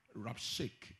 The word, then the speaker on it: Rabshakeh: Then the Rabshakeh, in fact, let me pronounce it. Rabshakeh. The H is silent.